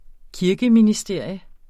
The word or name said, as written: kirkeministerier